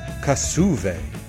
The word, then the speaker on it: Kasshuve: We got Kasshuve and that's how you pronounce the name of the band from Sweden.